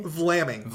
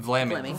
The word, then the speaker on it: Vlaming: Virginia Peter Flaming? – Vlaming. – Vlaming. – Vlaming. Uh-huh. Yes.